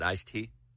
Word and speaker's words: iced tea: So you just weren't, it wasn't you weren't pronouncing the D, you didn't think there was a D there. – No, it's iced tea. – I-C-E space. – It's iced tea. – T-E-A.